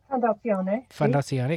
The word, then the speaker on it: Fondazione: I don't know how to say it. Fondazione. Is that correct? – Fondazione.